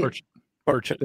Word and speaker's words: Burchett: How do you say Burchett. – It? Burchett. – Yeah, Burchett.